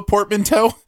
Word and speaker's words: portmanteau: It's a portmanteau. Is that how you say it? – Portmanteau mm-hmm.